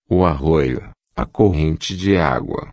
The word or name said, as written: arroio corrente de agua